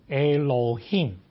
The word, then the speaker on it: Elohim: 这个名字呢，在原来的发音里面就是 Elohim。